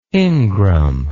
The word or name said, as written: ingram